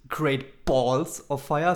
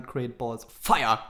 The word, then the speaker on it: Great Balls of Fire: äh, Jerry Lawler mischte sich dann auch ein und hat er geschrieben, der Pay-Per-View heißt ja nicht Great Balls of Fire, sondern Great Balls of Fire. Ja, die Betonung liegt auf Fire.